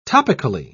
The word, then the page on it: topically: British English